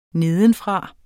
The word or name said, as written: nedenfra